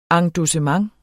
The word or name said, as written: endossement